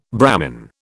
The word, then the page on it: Brahmin: British English